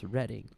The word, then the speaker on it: Reading: Here's why I say that, because there is an English soccer club that you would call Reading FC, but it's actually pronounced Reading.